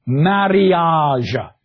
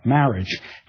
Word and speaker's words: mariage: You pronounce everything. Mariage, not marriage. Knicht, not knight. Vocabulary.